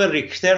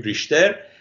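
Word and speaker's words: Richter: یک دانشمندی به نام ریکتر، حالا که به نام ریشتر هم ما میشناسیم، تلفظ آلمانیش رو باید در نظر بگیریم، اونا که بهتر آلمانی صحبت میکنن بهتر تلفظ میکنن، ما حالا بهش میگیم ریشتر، تو آمریکا معمولا میگن ریکتر، آلمان ها جور بهتری میگن.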